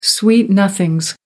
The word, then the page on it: sweet nothings: American English